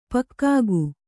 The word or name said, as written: ಪಕ್ಕಾಗು